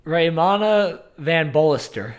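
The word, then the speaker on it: Raimana van Bastolaer: They stayed on a 112-foot catamaran, which was set up by Raymana van Bastelaire. I'm turning into Buck right now. How do you say his name? – Raymana van Bolster.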